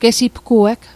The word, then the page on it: gesipgueg: Mi'gmaq